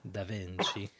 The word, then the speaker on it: Da Vinci: I think Da Vinci said that. – I thought I made that up. – No. – And you said it wrong. Da Vinci.